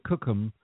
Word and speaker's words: Cookham: No, I'm in a small village called Cookham. Cookham, oh, okay.